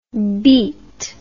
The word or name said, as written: bete